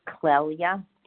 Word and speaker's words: Clelia: Hi, my name's Clelia. It's very hard. – How do you spell that? – C-L-E-L-I-A, Clelia. – Oh, sorry. Clelia.